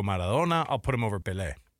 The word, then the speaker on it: Pelé: Yeah, got to throw that accent on the last one. – It's Pelé.